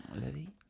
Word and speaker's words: Lévis: I think that's what it's pronounced. What is it? Anyway. – Lévis.